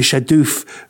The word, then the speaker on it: shadoof: Well, I'll spell it for you. S-H-A-D-O-O-F. You see, this is like a footballer that's come over. And I'm going, oh, it's shadoof. Shadoof, he's left back now for Arsenal. And in about 3 weeks' time, we go, no, it's shadoof. That's how you pronounce it.